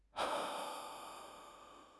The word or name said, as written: le5